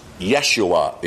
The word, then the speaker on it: Yeshua: And the Greek is really a form of the Hebrew name, Joshua, pronounced Yeshua in Hebrew.